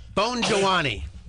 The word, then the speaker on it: Bongianni: and it's pronounced Bongianni. Bongianni.